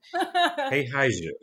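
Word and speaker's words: Heihaize (黑孩子): I was struck by your description of, and I know I'm going to say this wrong, Heihaize. Is that correct? – Very good. – Thank you. Heihaize.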